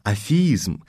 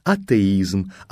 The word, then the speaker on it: атеизм: А вот, скажем, другое, более редкое греческое слово «атеос» – безбожный, происходящее от того же корня, долго передавалось у нас как «афей», «афеизм» через «фиту». Лишь к концу XIX века установилось наше нынешнее его произношение и правописание «атеизм», «атеистический» и тому подобное.